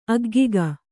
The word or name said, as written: ಅಗ್ಗಿಗ